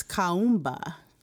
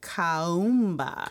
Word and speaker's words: Kuumba: It's Kaumba. – Kaumba. – Kaumba. – Kaumba. – It's spelled K-U-U-M-B-A. So it's like Kaumba.